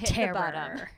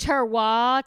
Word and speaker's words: terroir: Tannins and terror. – So many podcast spinoffs. – They're God. – It's pronounced terroir. It's not terror.